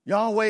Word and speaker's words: Yahweh: Yahweh is the way we pronounce it because we don't know the vowels that go with YHWH in the Hebrew, the tetragrammaton.